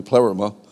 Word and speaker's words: pleroma: Pleroma, which I pronounced last week, pleroma, it's actually pleroma, is an ancient, comes to them from an ancient Greek word.